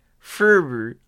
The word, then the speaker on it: Frewer: Frewer. It's F-R-E-W-E-R, and the only way I know how to pronounce that is just Frewer. F-R-E-W-E-R.